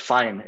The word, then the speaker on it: Fain: Yeah, well, we're going to shorten it to like Fine, F-A-I-N. It's a little easier.